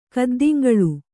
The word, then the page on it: ಕದ್ದಿಂಗಳು: kaddiŋgaḷu